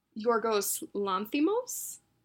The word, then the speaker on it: Yorgos Lanthimos: It's this little movie called The Lobster, directed by Yorgos Lanthimos. Correct me if I'm wrong on the pronunciation. – Yeah, that's right.